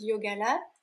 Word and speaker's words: Yogalates: Bah, en fait, mes réseaux sociaux, donc ma page Facebook, mon compte Instagram et mon site internet, c'est Yogalat. ça s'écrit Y-O-G-A-L-A-T-E-S. Et mon podcast, c'est Yogalat, le podcast.